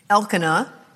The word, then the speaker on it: Elkanah: Elkanah, that's how you say his name, that's the husband, had two wives.